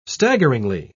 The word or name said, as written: staggeringly